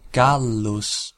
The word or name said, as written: Gallus